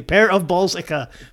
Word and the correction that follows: Parabolica: Parabolica. – Parabolica. Thank you for correcting me.